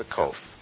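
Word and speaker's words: Kaph: That's a COF. COF. Very much like a K sound in English. Pronounced COF.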